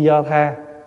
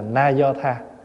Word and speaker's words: na do tha: Câu Chi do tha, có khi chữ do tha là mình đọc gọn, đọc đúng là na do tha.